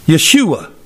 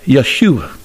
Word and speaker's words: Yeshua: Yeshua. That's how they say it in the Middle East. Yeshua. We in America call it Joshua.